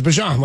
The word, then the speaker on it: Bijan: Yeah, it's Bijan, like the mustard.